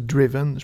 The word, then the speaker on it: Driven: Puis je veux pas faire mon petit duo connaissant ou quelque chose de genre, mais tu sais, la prononciation, ce serait plus Driven, je pense.